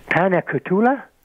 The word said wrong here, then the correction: paniculata: Oh, paniculata. Yeah, no, that's fine.